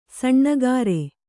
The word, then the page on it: ಸಣ್ಣಗಾರೆ: saṇṇa gāre